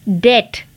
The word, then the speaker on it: debt: डेट